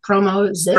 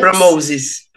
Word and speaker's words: PromoZiS: how do you pronounce that? PromoZiS. – PromoZiS.